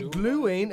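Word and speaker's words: Glühwein: Right, Gluein. It's spelled Gluhwein.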